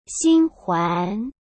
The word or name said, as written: xinhuan